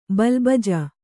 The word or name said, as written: ಬಿಲ್ಬಿಜ್ಜೆ